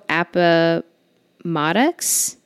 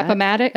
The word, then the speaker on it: Appomattox: He was also in Toe Appomattox. I'm sorry that I butchered that. – Appomattox. – Okay, thank you.